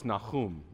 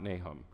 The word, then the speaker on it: Nahum: Also, it's Nahum, not Nahum.